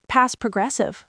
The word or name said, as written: past progressive